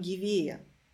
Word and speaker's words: гевея: Я увидела, что ударение ставится на первый слог, латинское слово, в словаре Залезняка первый слог. Женя говорит, ну мы говорим гевея.